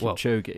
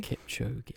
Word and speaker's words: Kipchoge: Kipchoge. – Well, say it again. – Kipchoge? – Kipchoge.